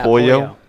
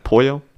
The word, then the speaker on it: Pollo: P-O-Y-O. Say it like that. – Pollo. Pollo.